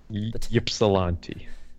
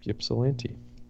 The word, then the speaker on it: Ypsilanti: Ypsilanti.